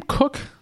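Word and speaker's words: Koch: Longtime Ravens punter, is it Sam Cook? Is that how you pronounce it? – It is.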